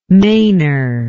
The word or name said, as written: mainour